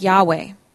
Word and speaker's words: Yahweh: Whenever you see the word L-O-R-D capitalized, Lord, you know that that is the name behind it. In Hebrew, the name had four letters, Y-H-W-H, and may have been pronounced something like Yahweh.